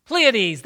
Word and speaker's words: Pleiades: How do you say it? Pleiades, that's it.